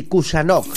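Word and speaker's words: Khusanov: Así, Kushanov. Kushanov. Termina en V, se pronuncia con F.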